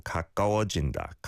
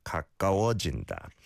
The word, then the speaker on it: 가까워진다: I don't think there's too much of a difference between the ka and ga, right? It's more that the ga kind of almost blends onto the previous syllable. 가까워진다, 가까워진다.